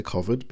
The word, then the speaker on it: COVID: And as mentioned in earlier uh, I say COVID because it rhymes with Ovid, because Ovid's a, uh, you know, the Latin poet, that word exists.